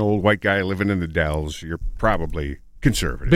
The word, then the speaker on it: The Dalles: Dallas with an E. Is that how you pronounce that? D-A-L-L-E-S? – The Dells. – The Dells. – The Dells, Oregon. – Or the Dalles or the Dells? – No, it's called the Dalles. – The Dalles. Okay.